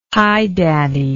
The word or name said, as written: highdaddy